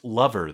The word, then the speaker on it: Leuver: So her name is Sarah, but her last name, which is spelled L-E-U-V-E-R, and looks vaguely French. And I'm going to say it's pronounced lover, though, because in her Twitter bio, she says lover of cartoons and comics, hence the name.